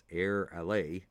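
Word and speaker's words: AirAllé: That's AIRALLE with an accent on the E, so I guess it's Air Ally.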